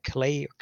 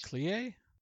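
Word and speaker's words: Klei: 2013, the original Don't Starve by Clay. How do you pronounce that? Entertainment. – Clay? – No, it's K-L-I-E-I. K-L-E-I. That would be Clay in German, or Clay, I don't know.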